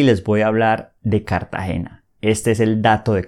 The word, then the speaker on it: Cartagena: Primero, quiero que aprendan a pronunciarla. Es Cartagena. C-A-R-T-A-G-E-N-A. Cartagena. No digan Cartagena, porque se van a dar cuenta que ustedes son turistas y les van a cobrar más. Es Cartagena.